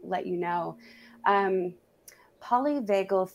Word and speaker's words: polyvagal: am I gonna say it wrong? Polynagal. – Polyvagal polyvagal theory. Polyvagal theory.